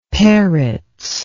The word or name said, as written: Peretz